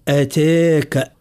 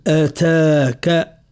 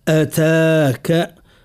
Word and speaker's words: أتاك: نعتني بحرف التاء نرقق صوته بدون مبالغة، لأن فما من يبالغ في ترقيق حرف التاء: أتاك، وهذا ترقيق المبالغ المنهي عنه. وإن شاء الله النطق الصحيح لحرف التاء: هل أتاك أتاك أتاك.